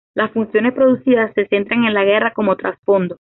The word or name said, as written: funciones